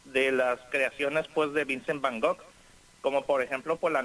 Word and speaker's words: Van Gogh: Bueno, pues se trata de la historia y trayectoria de este pintor neerlandés, o de Países Bajos, Vincent Van Gogh. Es como se pronuncia. – Ah, Vincent Van Gogh, ajá, Vincent Van Gogh. – Uy, yo quiero oír, quiero oír, ¿eh? – Sí, Van Gogh es como se pronuncia realmente el apellido, pero bueno.